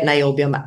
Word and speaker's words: niobium: And if you're lucky enough to get niobium outside of Brazil, I think that's an interesting bet. – Well, tiny market. – Yeah, interesting. How do you pronounce that? – Niobium.